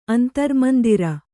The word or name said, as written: ಅಂತರ್ಮಂದಿರ